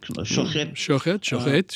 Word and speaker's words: shochet: You'll have to forgive my pronunciation. Was very likely the Shochet. Is that correct? – Shochet, sure.